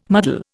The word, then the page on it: muddle: Indian